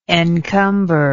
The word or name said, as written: encumberment